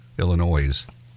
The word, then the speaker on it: Illinois: That's how you say it, Illinois.